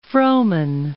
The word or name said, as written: Frohman